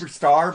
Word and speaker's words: Super Czar: Superstar, but it's spelled Super Czar.